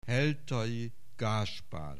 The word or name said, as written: Heltai Gáspár